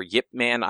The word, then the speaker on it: Ip Man: But do you guys want to talk about Ip Man or Yip Man? I don't know. However you say it. – I believe it's pronounced like Yip Man, like, with a Y, but the Y is very, very subtle.